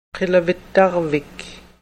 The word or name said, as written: qilavittarvik